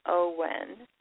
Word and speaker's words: Eoghan: It's the Gaelic version. It's pronounced Owen, but it's E-O-U? No. – Mm-hmm. – Tell me. – E-O-G-H-A-N. – Uh-huh. E-O. Ochen is probably something like the Gaelic pronunciation, and it does look like it would be pronounced sort of like Owen.